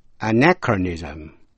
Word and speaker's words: anachronism: CH cũng phát âm là K trong chữ anachronism. Anachronism, người hay điều cổ không hợp thời. Và chữ stomach, S-T-O-M-A-C-H, stomach, CH đọc là K.